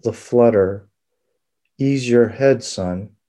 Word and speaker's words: Son: It's called Sun Sutra, and Sun is spelled S-O-N, but obviously has other meanings.